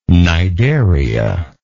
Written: cnidaria

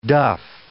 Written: duff

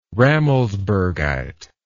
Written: rammelsbergite

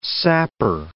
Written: sapper

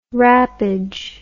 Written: wrappage

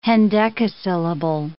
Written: hendecasyllable

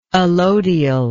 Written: allodial

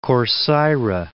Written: Corcyra

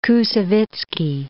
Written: Koussevitzky

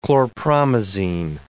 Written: chlorpromazine

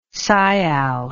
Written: sial

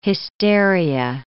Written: hysteria

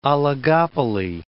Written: oligopoly

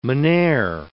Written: mynheer

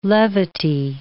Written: levity

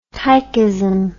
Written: tychism

